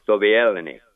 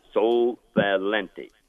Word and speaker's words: Sovaleni: Well, I will give you the prime minister's name and number, and the prime minister is Siyasi Sovieleni, and that's spelled S-I-O-A-S-I. That's the first name. S-O-V-A-L-E-N-I. Sovieleni, or Lenny, excuse me.